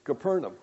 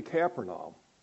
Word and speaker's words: Capernaum: I've been kind of taught in the Israeli pronunciation, of which our guide kept correcting, thankfully. And that. It's actually Capernaum. Capernaum, yeah. This is the way the Israelis would pronounce it.